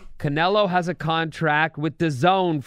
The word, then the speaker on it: DAZN: DAZN. – DAZN.